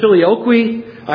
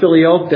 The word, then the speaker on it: Filioque: Some pronounce it Filioque. I pronounce it Filioque. That's an acceptable pronunciation.